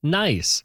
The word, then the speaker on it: gneiss: And yes, spelled with a G, but it is pronounced nice.